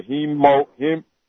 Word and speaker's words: hemangioma: My medical diagnosis, I have what they call a liver, a hemangioma. I can't never say it. How do you say that? – Hemangioma? – yeah.